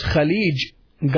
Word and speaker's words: Khalij: Then there is Khalij, Gulf. Khalij, Gulf. Kha, Lam, Ya, Jim.